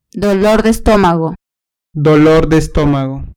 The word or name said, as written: dolor de estomago